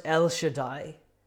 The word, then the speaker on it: El Shaddai: In Hebrew it's pronounced El Shaddai.